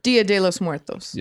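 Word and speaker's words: Día de los Muertos: Dia de los Muertos.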